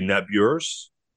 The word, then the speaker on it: Nabuurs: And it's actually the pronunciation in the Netherlands would be Nabors. And so my family Canadianized it a little bit. My favorite story to tell, Scott, about the two U's. So N-A-B-U-U-R-S.